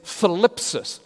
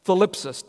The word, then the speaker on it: thlipsis: The Greek word for tribulation is philipsis. Let me spell that for you. T-H-L-I-P-S-I-S. Philipsis. T-H-L-I-P-S-I-S.